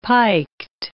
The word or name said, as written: piked